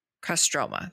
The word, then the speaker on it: Kostroma: And um, during the festival, there is a straw figure that portrays Kostromina. Kostra. Kos. Why did I forget how to say this? Kostroma. There we go.